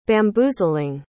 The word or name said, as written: bamboozling